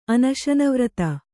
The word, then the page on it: ಅನಶನವ್ರತಿ: anaśanavrati